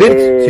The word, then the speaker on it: Wirtz: Con la de Florian Wirtz, creo que es la pronunciación. – Florian Wirtz, sí. – Sí. – W-I-R-T-Z. – Wirtz. – Birds. – Exactamente. Bueno.